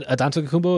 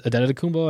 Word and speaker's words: Antetokounmpo: I said Antetokounmpo and it's Antetokounmpo.